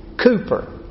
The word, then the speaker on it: Cowper: His last name looks like Cowper, but the English actually pronounce it Cooper.